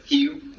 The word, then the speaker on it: rio: And the Portuguese word is rio, where the R is pronounced like an H for some reason.